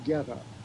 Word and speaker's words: Goetta: I'll spell it Goetta, and it's pronounced Getta, like get a life or.